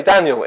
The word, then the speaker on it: Daniel: And at first he was proved to be right, because as he spoke, and particularly as he pronounced the word Daniel," he liked to tell the story of Daniel in the lion's den as part of his talks. He managed to say apparently in one syllable.